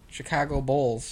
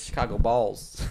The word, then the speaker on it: Chicago Bulls: No, it sounds like you're saying balls. – Chicago Bulls. – Chicago Balls. – I guess I could see that. – Bulls. – That's just how I pronounce bowl. – It sounds weird. It sounds super weird.